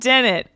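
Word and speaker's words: Dehnert: My name is Kate Dennett, and that is spelt K-A-T-E-D-E-H-N-E-R-T.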